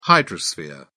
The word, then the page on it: hydrosphere: British English